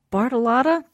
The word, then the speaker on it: Bartolotta: Very excited to finally have Kate Bartolotta.